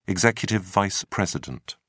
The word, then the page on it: executive vice president: British English